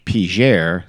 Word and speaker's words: Peigneur: It is spelled P-E-I-G-N-E-U-R. So in French, I don't know, Pignor or something like that. But the locals pronounce it Piger, so I'm going to pronounce it Piger.